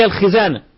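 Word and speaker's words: الخِزانة: ولا تفتح الخزانة، الخزانة النطق الصحيح فيها الخزانة، والبعض كما قلنا بيخطئ فيفتحها يقول خزانة، فهو يقول لك لا تفتح الخزانة خليها مقفولة، ده المعنى المتبادر، لكن المقصود لا تفتح الخزانة يعني خليها بالكسر.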